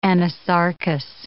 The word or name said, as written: anasarcous